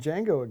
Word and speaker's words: Django: So good that you're saying Django again. I know. I kept saying the Django for the longest time. the D is silent.